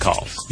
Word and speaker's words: caul: and that's birth calls. – Yeah, and it's C-A-U-L is how it's phonetically spelled, but I believe it's pronounced call, like, you know, you would make a phone call, right? – Yeah.